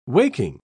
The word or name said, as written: waking